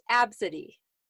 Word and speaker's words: Abcde: So okay, so the next one is spelled A B C D E. Do you know how that would be pronounced? That is pronounced Absidy.